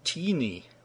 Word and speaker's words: Tiny: The bishop's name is Tiny Muskens, though I think that first name is pronounced in Dutch as Teeny.